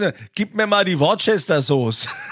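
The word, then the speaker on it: Worcestershire sauce: Worcester Sauce. – Aber das wird doch ganz anders geschrieben. Ja, aber es ist Worcester Sauce.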